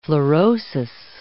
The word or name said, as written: fluorosis